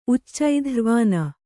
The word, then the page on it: ಉಚ್ಚೈಧ್ರ್ವನಿ: kannada